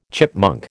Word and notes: chipmunk: American English

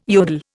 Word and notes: yodel: Indian

